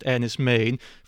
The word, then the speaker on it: Enys Men: I know it looks like Enys Men, but it is pronounced Enys Men, from last year, 2022.